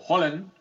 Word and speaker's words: Haaland: Les pedimos a la gente que siga mandando sus sugerencias sobre los apodos para Erling Haaland, o Haaland, como le dice él, como le dicen en Noruega, en la pronunciación correcta de su apellido.